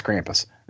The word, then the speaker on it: Krampus: I know you keep calling it Krampus, but I'm sorry, it's Krampus.